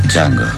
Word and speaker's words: Django: Django. – Sai come si scrive? – D-J-A-N-G-O. La D è muta.